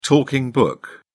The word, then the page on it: talking book: British English